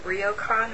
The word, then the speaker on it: Ryokan: And now, at the start of your book of poems, While We've Still Got Feet, you have an epigraph from Ryokan. Am I saying that right? – Yeah, that's fine.